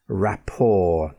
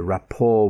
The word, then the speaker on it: rapport: Now, just in case you didn't know this, words that have an origin in French and end with the letter T also have a silent T sound. For example, the ballet, not the ballet, the ballet. Gourmet cooking, not gourmet, gourmet cooking. Rapport, the rapport with his origins, the connection with his origin, rapport.